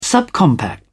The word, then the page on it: subcompact: British English